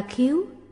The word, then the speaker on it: xác: Chữ xác các vị có người đọc là khái, thực ra độc là khiếu, có nghĩa là vỏ cứng.